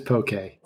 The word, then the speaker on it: poke: First of all, am I saying it correctly? It's poke rather than pokey? – It depends on who you talk to. I mean, the right way to say it is poke.